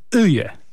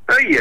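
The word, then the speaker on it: Øye: Odpowiedziałem, Erlend Öje. – Öje, yes, Öje, very good, very good.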